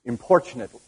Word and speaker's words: importunate: If you change it into an adjective, according to my dictionary, the T becomes like a CH, importunate widow.